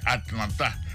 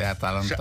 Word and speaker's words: Atalanta: Je vais m'apprendre une confidence ouais pour une femme de nom Atalanta me comprendre c'est mal ou t'es mal prononcé Atlanta. Ah, c'est une grande ville qui existe dans l'État Géorgie, dans le Pacifique Sud, mais il est une belle ville. – C'est Atalanta Bergamo.